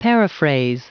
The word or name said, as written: paraphrase